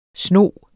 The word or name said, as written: SNOGENE